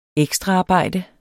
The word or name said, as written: ekstraarbejde